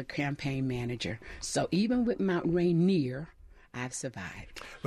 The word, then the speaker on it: Mount Rainier: The correct name, if you're an old timer, is Mount Rainier. And I always say it's rainier in Mount Rainier than anywhere else on earth.